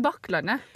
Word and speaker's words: Bakklandet: For vi snakka litt med mammaen til Mari, syns at uh, hun må liksom uttale uh, eh, Bakklandet, er det det man skal si? – Man sier Bakklandet.